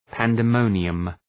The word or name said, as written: pandemonium